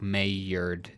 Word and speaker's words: Maillard: So I can't, I don't know that I can pronounce it, but I think it's that, like, it looks like Maillard if I'm yes. – pronouncing the L's like a y.